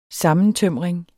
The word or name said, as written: sammentømring